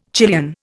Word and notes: Chilean: Indian

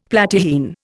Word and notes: Platyrrhine: Indian

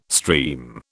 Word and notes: stream: British English

